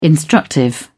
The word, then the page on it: instructive: British English